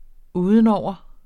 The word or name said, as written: udenover